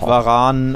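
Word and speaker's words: Varane: Varan und endlich ja. richtig ausgesprochen übrigens.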